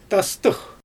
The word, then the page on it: tastɯx: Xyzyl